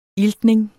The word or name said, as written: iltning